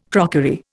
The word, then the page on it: crockery: Indian